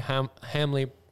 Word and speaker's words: Hamele: Travis is the owner and founder of Midwest Lifestyle Properties, formerly Hamley Properties and Auctions. Am I saying that right? Mm-hmm.